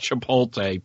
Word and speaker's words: Chipotle: And yes, I know it's pronounced Chipotle, people.